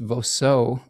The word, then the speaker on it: Voceux: I believe it's pronounced Voceux, V-O-C-E-U-X.